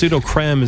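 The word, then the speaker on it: Sudocrem: It used to be Soother Cream, and then because of the Dublin accent, Pseudocream. – Pseudocream. – They changed it to Pseudo. It's Pseudocream is the correct pronunciation, apparently.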